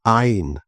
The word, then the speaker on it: ein: Wir hören und lesen es wiederum in der Fassung der Elberfelder Bibel, nämlich: Höre Israel, der Herr unser Gott ist ein Herr. Betonung auf ein.